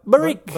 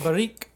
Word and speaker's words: Barrique: The brewery is called Barrique, and it's spelled Barrique. Almost like Baroque, but Barrique. – Barrique.